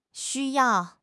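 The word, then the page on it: xu1 yao4: tone 1; tone 4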